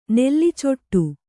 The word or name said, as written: ನೆಲ್ಲಿಚೆಟ್ಟು